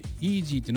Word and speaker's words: EZY: 今日紹介するのは機動警察パトレイバーイージーファイル 2 ということで、イージーっていうのは EZY と書いてイージーって読ませるということで、 8 月 14 日公開、日本のアニメーション作品、尺は 70分。